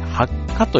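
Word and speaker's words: 白華: えー、白い花と書いて、花ってあの、えっ、ー、と、中華の蚊ですね。白い中華の蚊と書いて発火という現象なんだそうです。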